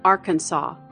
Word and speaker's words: Arkansas: A controversy had raged over the correct way to pronounce the state's name, and this was settled by adoption of an official pronunciation, Arkansas.